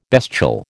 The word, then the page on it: bestial: American English